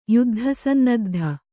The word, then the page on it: ಯುದ್ಧಸನ್ನದ್ಧ: kannada